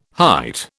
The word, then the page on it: height: British English